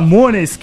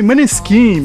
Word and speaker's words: Måneskin: A gente fala Mones, que é o nome oficial com a pronúncia italiana, mas aqui no Brasil o pessoal chama de Maneskin mesmo, daquela música do Begin, que toca aí todo o rolê, toda a balada.